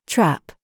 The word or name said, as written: TRAP